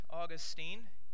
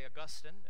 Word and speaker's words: Augustine: His name is Augustine. You might say Augustine. Each pronunciation is correct.